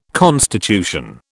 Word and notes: constitution: British English

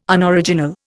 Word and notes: Unoriginal: Indian

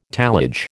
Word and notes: tallage: American English

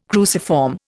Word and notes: cruciform: Indian

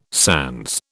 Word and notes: sans: British English

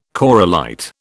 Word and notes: corallite: British English

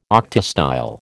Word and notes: octastyle: American English

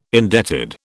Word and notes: indebted: British English

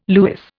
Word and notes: lewis: Indian

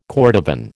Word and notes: cordovan: American English

